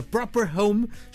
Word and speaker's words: Proprhome: Eles fazem parte de uma plataforma que chega ao mercado, direcionada para o ramo imobiliário, chamada Proper Home. Estou a pronunciar bem, tenho sempre medo estas coisas de estrangeirismo. Estou a dizer bem? – Perfeitamente.